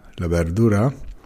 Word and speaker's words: la verdura: Und das V bei verdura, la verdura, wird eigentlich eher wie ein B ausgesprochen.